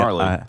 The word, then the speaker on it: gnarly: A little gnarly. – It's that silent G.